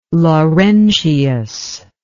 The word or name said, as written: Laurentius